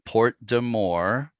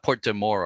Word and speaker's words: Porte des Morts: I didn't say that quite right. But Porte de Morts, this name of the strait. And I think that may be approximately how local people pronounce it.